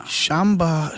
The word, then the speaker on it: Shambhala: And it's, how do you say it? Shambhala? – Shambhala.